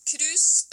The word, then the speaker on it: Krus: But speaking of Norway and wool, you also have a new project that you call Cruise, if I say it correctly. Can you talk a little bit about that project? – Cruise, spelled K-R-U-S, is the Norwegian word for crimp.